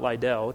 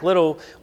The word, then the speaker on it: Liddell: It's Little, not Liddell. It's spelled Liddell, but it's Little.